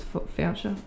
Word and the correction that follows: Fouché: I can't pronounce her last name. – Yeah. – Fouché, that's it. You said it wrong.